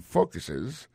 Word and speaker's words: focuses: Ça nous carré là, nous carré que, dans mot focus là, accent tombé sur premier syllabe là, this program focuses on requesting.